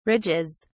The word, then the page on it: ridges: British English